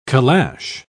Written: calash

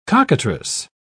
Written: cockatrice

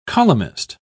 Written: columnist

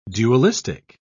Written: dualistic

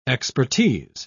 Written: expertise

